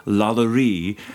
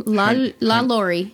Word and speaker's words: LaLaurie: She's known as Madame LaLaurie, or LaLaurie, or LaLaurie, I would imagine, because it's a name of French extraction. – Madame LaLaurie? – Madame, but it's spelled L-A-L-A-U-R-I-E. – Oh, Laurie, like from Oklahoma.